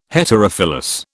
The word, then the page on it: Heterophyllous: British English